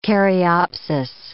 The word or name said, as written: caryopsis